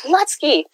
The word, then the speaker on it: Lutzke: We are here today with two incredible authors, Tim Meyer and Chad Lutz. Am I saying your name right? – Lutzke.